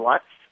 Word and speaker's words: Lutz: His name is Kellan Lutz, and it's not a coincidence that it rhymes with guts.